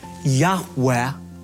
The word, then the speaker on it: Yahweh: When I say God's sacred name, which most Semitic scholars believe is pronounced Abrathi, Yahweh, I say that very reverently.